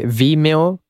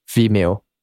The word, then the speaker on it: Vimeo: che Vimeo si pronuncia come video, ma al posto della D c'è la M, quindi video Vimeo.